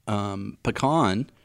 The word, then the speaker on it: pecan: Pecan, which I feel like we're saying it right, right? – Not pecan. – Pecan. There's also a couple other, I saw that there's a couple other, even English people say it a different way, but it's regionally pronounced. Okay. We go with pecan. – Pecan. – But I think down south it's like pecan. Pecan pie.